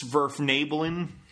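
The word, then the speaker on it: Svirfneblin: I believe it's Svirfnablin.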